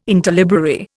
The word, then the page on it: interlibrary: Indian